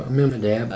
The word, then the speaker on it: Amminadab: And Aram begat Amminadab. Did I say that right? – Amminadab.